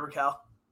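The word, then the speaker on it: Rakell: Raquel, is that how you say it? – Yeah, Ricard Raquel. – It's Ricard, sorry. Ricard Raquel, left wing, right wing, UFA at the end of the year.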